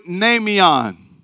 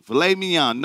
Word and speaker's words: mnemeion: How you pronounce it? Somebody said, filet mignon. No, nameion. Where filet come in at? Filet mignon, no, nameion, not filet, nay. M-N-E-M-E-I-O-N.